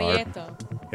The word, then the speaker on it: Prieto: Shout out to Isaac Prieto, by the way. And I know I pronounced that wrong. I got to roll that R. – Prieto.